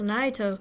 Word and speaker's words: Naito: And I believe they fought each other at How did you say his name? – Is it Naito? – Yeah, it's Naito. – Naito.